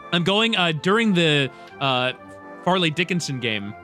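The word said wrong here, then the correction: Fairleigh Dickinson: Did I say that correctly? Farley? – Fairly Dickinson game.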